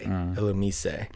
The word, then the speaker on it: Illumise: The Japanese pronunciation is Illumise. The S-E at the end makes a say. Illumise.